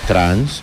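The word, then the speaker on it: trance: No, trans, así se pronuncia.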